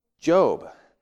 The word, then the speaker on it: Job: I can see the book of Job, but what about Job? Again, that's just another thing we take for granted. The letters J-O-B, we pronounce Job in the Bible, not Job.